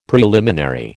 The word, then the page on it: preliminary: American English